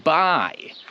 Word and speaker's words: bi: To ten prefix się wymawia bi. Bi.